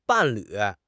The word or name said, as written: 伴侣